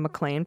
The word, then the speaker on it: McLean: And I'll kick this off with McLean, a submission from McLean, pronouns he and him. And they felt the need to say pronounced McLean, as in the characters A.G.'s cat played in Die Hard, John McLean.